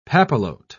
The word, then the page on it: papillote: British English